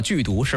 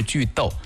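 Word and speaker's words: 句读: 很多人说太简单了，剧毒是吧？其实不对，应该是剧逗，这个逗通逗号的逗，剧逗简单来讲就是断句的意思。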